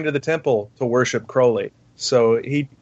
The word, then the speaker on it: Crowley: reminding you throughout the essay it's pronounced Crowley, rhymes with holy.